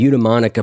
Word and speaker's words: eudaimonic: Eudaimonic? – Sure. – Okay, eudaimonic.